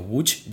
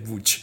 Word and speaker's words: Łódź: Y después, a partir del 10, pues hemos estado en Gronniki, que es un pueblo cerca de Łódź, que si lo buscáis se escribe Lodz, L-O-D-Z, pero claro, cuando llegué allí y los polacos estaban diciendo Łódź, yo decía, ¿eso qué es? No, es que Lodz se dice Łódź.